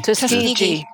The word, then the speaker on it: Tuskegee: Tuskegee.